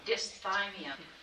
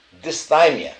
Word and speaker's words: dysthymia: And then it says, while unipolar depression and dysthymia are the primary forms of depression, a variety of other subtypes exist. So this is unipolar depression and chronic depression, dysthymia. What? – Dysthymia. – Dysthymia, thank you.